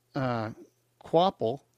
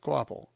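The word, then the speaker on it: Kvapil: The car, to be driven by renowned NASCAR star Travis Quapple, I hope I said that right, Quapple, it's K-V-A-P-I-L, Quapple, I'm pretty sure I'm saying that right,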